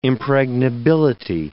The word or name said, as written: impregnability